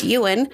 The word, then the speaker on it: Yuen: Monograph CEO and co-founder Robert Yuen. I think that's how you say it. Y-U-E-N. – That's how I would say it.